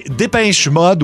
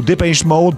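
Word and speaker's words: Depeche Mode: OK, Dépêche Mode ou Dépêche Mode, peu importe comment on le prononce.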